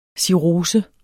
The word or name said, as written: cirrose